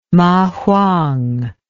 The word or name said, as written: mahuang